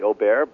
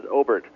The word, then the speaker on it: Obert: It's kind of like Robert without the R, and it's pronounced Obert just like it sounds. I lived in France for a long time, and a lot of people call me Aubert, but the correct pronunciation is Obert.